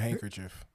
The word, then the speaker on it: handkerchief: H-A-N-K. H-A-N-K? – H-A-N-C. – A hint, one of the letters is silent that you're missing. – Oh, damn. Wow. Yo, what? – A handkerchief.